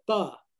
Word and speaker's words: pho: It's called Pho, and it's Vietnamese soup. It's spelled FOH.